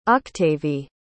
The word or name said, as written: octavie